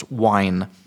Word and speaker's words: whine: It was like whining, which is when they go, that's whine with a, not whine the drink, whine with an H. Wuhine. But the H is silent.